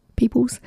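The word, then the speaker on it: Peeples: It's spelt Peep, not P-E-O, but P-E-E, Peep, Peoples, slouching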